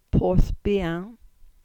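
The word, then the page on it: Porthbian: Cornish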